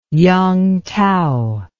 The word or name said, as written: yangtao